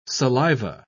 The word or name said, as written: saliva